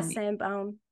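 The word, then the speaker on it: Sainte-Baume: I've so wanted to go to all of those places, to Saint Baum. That's how you say it, right? Yeah, Saint Baum. – Yeah, Saint Baum.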